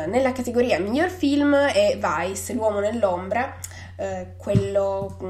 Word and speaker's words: Vice: infatti il titolo è Vice, che noi pronunciamo chiaramente all'inglese, però sai, vice, quindi si capisce insomma di che cosa parla.